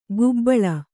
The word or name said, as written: ಗುಬ್ಬಳ